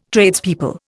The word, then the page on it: Tradespeople: Indian